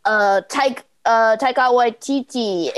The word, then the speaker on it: Taika Waititi: Jodie Comer, Joe Geary, Taika Waititi, incredible performances, and yeah.